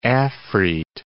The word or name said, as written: afrit